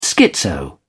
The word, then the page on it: schizo: British English